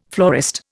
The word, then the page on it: florist: Indian